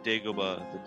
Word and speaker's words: Dagobah: The correct pronunciation is, yes, Dagobah, the Dagobah system.